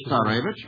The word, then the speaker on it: Tsarevich: Tsarevich.